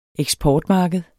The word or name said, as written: eksportmarked